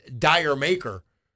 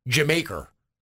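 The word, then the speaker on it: D'yer Mak'er: It's the song that if you look at it, it looks like it's pronounced Dire Maker. But that's the way they were saying Jamaica. Jamaica. After the country.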